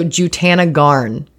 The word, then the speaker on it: Jutanugarn: So Jutanugarn.